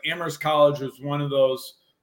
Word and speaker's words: Amherst: It's Amherst. Don't pronounce the H. You got to be a local. All right, Amherst.